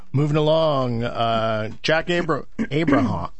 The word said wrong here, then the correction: Abramoff: Yeah, Jack Abramoff is the Republican connected lobbyist who has been is connected with Tom DeLay and various other Republican politicians.